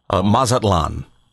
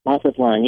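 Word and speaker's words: Mazatlán: Mazatlan. – Mazatlan, yes.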